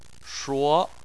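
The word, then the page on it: shuo2: tone 2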